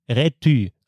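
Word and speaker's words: Räty: Übrigens, was ich auch nicht wusste, Akku Rety, also Y mit Ü ausgesprochen.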